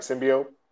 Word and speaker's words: symbiote: Oh, symbiote. – Symbiote. Did I say that right? Symbiote. – Symbiote. Yes.